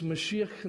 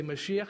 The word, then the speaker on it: Mashiach: In Hebrew, it's Mashiach Nagid. And you got to say it like you have phlegm in the back of your throat. Let's practice. Say Mashiach.